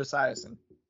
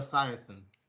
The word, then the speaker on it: Esiason: I don't know how to pronounce his last name. Isaacson or whatever. – Oh, Boomer Esiason. – Yeah, wow. Boomer Esiason.